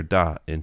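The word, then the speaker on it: Dai (大): of Tainan which is called in Mandarin it's called Da Wan, but uh, the that character Da in Taiwanese is pronounced Dai, oh, so it's oh, actually okay.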